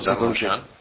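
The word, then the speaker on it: Darnauchans: Siempre que pienso en Eduardo Darnoyan, que así se pronuncia, ¿no? Darnoyan.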